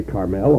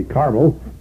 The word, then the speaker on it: Carmel: I like to pronounce this as a Hebrew word and say Carmel, although we usually say Carmel.